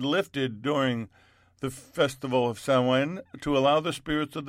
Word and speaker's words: Samhain: It's pronounced Samhain, but it's spelled Samhain festival.